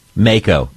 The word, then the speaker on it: mako: Mako.